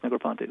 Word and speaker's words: Negroponte: Yeah, Nicholas Negroponte, that's right.